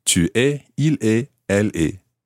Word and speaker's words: tu es: Tu es.